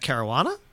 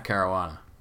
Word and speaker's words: Caruana: Is it Matt Casuarina? How do you say Matt's last name? – Sure. – Matt, what, as in Matt Caruana? – Yeah, that's the one, Matt Caruana.